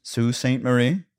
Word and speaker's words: Sault Ste. Marie: Sault Ste. Marie? – Yes. – All right. It looks like it's spelled Salty Ste. Marie.